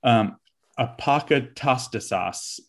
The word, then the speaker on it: apokatastasis: I'm going to butcher this word, apokatastasis, that's it. – Yes.